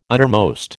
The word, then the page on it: uttermost: American English